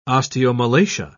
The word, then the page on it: osteomalacia: British English